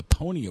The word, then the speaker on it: Pwnie: David Mayner and John Elk were both given a Pony Award. That's spelled P-W-N-I-E at this year's Black Hat Conference for their supposed hack for taking over a Mac.